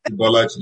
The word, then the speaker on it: Ibolachi: So now if I meet a Nigerian, say Ibolachi. Am I saying it right? – Ibolachi, yes.